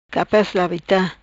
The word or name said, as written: Labita capaz